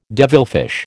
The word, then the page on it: devilfish: American English